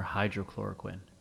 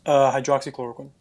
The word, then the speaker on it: hydroxychloroquine: Is it hydroxychloroquine or hydrochloroquine? – Hydroxychloroquine. – Oh, I said it wrong.